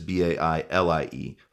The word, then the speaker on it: Bailie: Now you got to watch the spelling of Bailey. My last name is spelled Baillie.